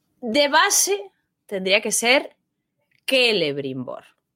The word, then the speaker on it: Celebrimbor: No lleva tilde. Termina en consonante. ¡Ah! Termina en consonante. O sea que es Celebrimbor. – ¡Oh! ¡Ciertamente! – ¡Qué fuerte! ¡Es Celebrimbor! – Celebrimbor.